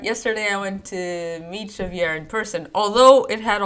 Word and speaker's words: Xavier: But in Javier. Portuguese, you write it with an X, and you pronounce it Xavier. Yes, it sounds Russian.